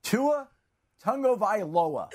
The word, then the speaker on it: Tua Tagovailoa: How should we all pronounce Tua's last name? – Tua Tagovailoa. Did I say that correctly? I believe I did.